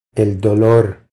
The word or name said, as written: El dolor